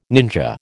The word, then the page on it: ninja: American English